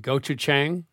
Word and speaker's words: gochujang: We did a pork shoulder recently with miso and gochujang, the Korean.